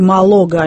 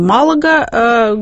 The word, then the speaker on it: Малага: Все-таки Малога, а не Малага, говорит нам словарь. Будем считать, что это так, хотя я слышала и другое ударение.